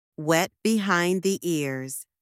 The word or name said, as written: wet behind the ears